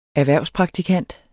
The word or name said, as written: erhvervspraktikant